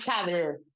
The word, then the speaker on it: Schabl: Sí, mi apellido es Schabl, es de descendencia alemana del Volga.